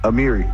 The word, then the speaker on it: Amiri: Amiri. – Amiri, like the clover line. – Good job.